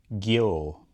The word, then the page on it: Geo: Shaetlan